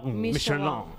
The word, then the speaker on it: Michelin: Ah, porque Michelin no se dice, se dice Michelin.